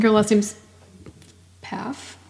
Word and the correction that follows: Pfaff: Faff. I think the P is silent. – Yeah. – P-F-A-F-F.